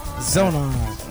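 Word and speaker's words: Xonar: This is Yvonne Emmerich, Zonar. – Zonar. – And that's spelled with an X. X-O-N-A-R.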